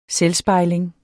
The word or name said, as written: selvspejling